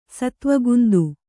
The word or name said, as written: ಸತ್ವಗುಂದು